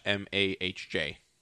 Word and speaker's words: homage: Because it says, it's hard to pronounce it. It's AA-MAHJ.